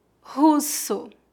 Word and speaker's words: russo: Lembre-se que o R em começo de palavras é pronunciado como a letra H no inglês. Russo. Observe que usamos dois S's entre duas vogais para ter o som S, como na palavra russo.